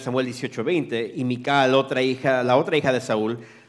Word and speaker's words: Michal: I've also heard it pronounced Michael. – También se puede decir Michael. – But it sounds, looks, if you look at it in the interlinear in the Hebrew, it looks like Michal. – Y pero si vemos en el idioma hebreo, la pronunciación más correcta sería Michal. – I think in the Spanish too, right? It's more pronounced Michal, right?